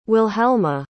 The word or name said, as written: wilhelma